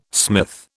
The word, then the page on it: smith: British English